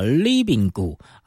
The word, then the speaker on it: RIBINGU: 非常活泼轻快的这首客语歌 RIBINGU 啊，是他们的团名，要怎么念呢？啊，叫 Libingu 啊，这个重音呢、啊，哎，可是你可以，哎，这个刚开始看到这英文单字 Libingu 啊。